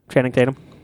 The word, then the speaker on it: Channing Tatum: He says Tanning Tatum all the time.